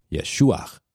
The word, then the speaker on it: Yeshua: I'm making a point about the fact that the name Jesus did not exist at that time period, and Jesus' name was actually pronounced Yeshua.